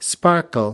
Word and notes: sparkle: American English

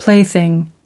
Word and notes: plaything: American English

